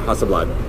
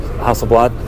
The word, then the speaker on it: Hasselblad: So, uh, Hasselblad is that how you say it? Yeah, Hasselblad. Hasselblad. Yes.